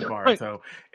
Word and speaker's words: Boruto: I remember making fun of Jason Gambrell the first time he talked about Naruto and Boruto. – Guys, don't keep butchering this man's name. His name is Baruto.